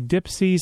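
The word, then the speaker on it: DIYPSI: Now, stuff's happening because it is the summer, and the Dipsy Summer Festival is coming up. That's spelled capital D-I-Y, lowercase, P-S-I. P.S., that's Dipsy.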